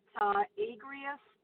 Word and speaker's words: Agrios: What they can do is they can go to my website at Agrius Mind Fitness. And so my name is spelled A-G-R-I-O-S, as in Susan, and then Mind, M-I-N-D, and then Fitness, F-I-T-N-E-S-S.